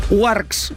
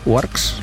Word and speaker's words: UARX: Warx, ¿se dice así? Warx, U-A-R-X. – Es española, ¿eh? – Bueno, pero yo es que... – U-A-R-X. – U-A-R-X, vale.